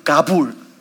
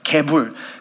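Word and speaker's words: 가불 (גְּבוּל): I want to focus on that word territory, which is 가불. 가불, or the other pronunciation is 개불. 근데 한국말로 개불이 조금 뉘앙스가 이상할 수 있어. 가불 or 개불 is the word.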